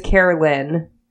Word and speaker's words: Carolyn: Her name is spelled C-A-R-I-L. I don't understand. And I don't think I can, like, talk too much because my name is Carolyn. – Right. – And then I've gotten Carol, Karen, Caroline, just things that aren't, Caroline's pretty close.